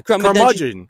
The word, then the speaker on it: curmudgeon: But, like, are you familiar with the term crema dungeon? – Probably saying it wrong. – Yeah, yeah. Curmudgeon.